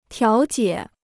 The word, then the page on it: 调解: tiáo jiě